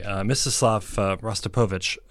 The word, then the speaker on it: Mstislav Rostropovich: Mstislav Rostropovich.